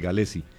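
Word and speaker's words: Gallese: Cuando Dudamel llegó, Galese dijo el mismo que se decía así, el mismo dijo que su apellido se pronunciaba así, Galese.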